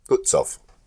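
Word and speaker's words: Lützow: Now, within the next 8 minutes, Invincible scored 8 hits on Litzow at a range of 9,600 yards. – It is definitely Litzow. It's L-U. – Yeah, Lutzow.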